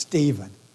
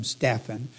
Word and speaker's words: Stephen: Dave does not like this name to be pronounced Stephen. Unfortunately, in some parts of the country, when I looked it up, they do say Stephen. Phonically, Dave is right. There is no V in here. But you know how English is. Names can be pronounced differently. But we will pronounce him Stephan.